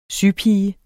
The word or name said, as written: Sypiger